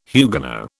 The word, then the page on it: Huguenot: British English